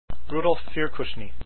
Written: firkusny

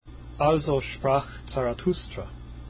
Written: Zarathustra

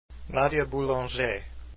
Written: boulanger

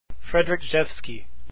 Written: rzewski

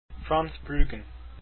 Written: brueggen

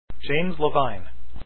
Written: levine

